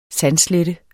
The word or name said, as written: sandslette